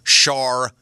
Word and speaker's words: Sade: Pronounced Sharday. – Sharday. – Shar-day. – Yeah. – And we were like, I don't think it is Sharday. – Where's the R? – Yeah.